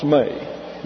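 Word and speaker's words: may (μή): This is a long E in the Greek pronounced like the English A. So you have, it's transliterated me, but it's pronounced may. Then you have another negative, ouk. O-U-K.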